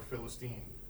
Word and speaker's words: Philistine: The largest beetle is named after which biblical Philistine? Or Philistine, depending on how you pronounce it.